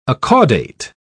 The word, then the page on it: acaudate: British English